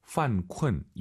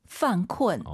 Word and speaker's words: 犯困: 犯困, 犯困, 이 발음을 잘 하셔야 됩니다. 쿤쿤 하지 마시고 UN 사이에 으어 들어가서 다시 한번 犯困.